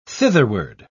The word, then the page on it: thitherward: British English